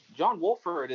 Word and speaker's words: Wolford: Is it John Walford? – Oh yeah, John bro. John Wolford is um so he played at he played at Wake, Wake Forest.